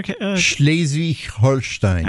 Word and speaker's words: Schleswig-Holstein: How do you say it again there? – Schleswig-Holstein. – I like how you say it.